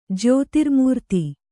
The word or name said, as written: ಜ್ಯೋತಿರ್ಮೂರ್ತಿ